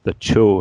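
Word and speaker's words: Chöd: And in Tibetan Buddhism, there's this ritual called the Cho, or the Chod. It's spelled like Chod.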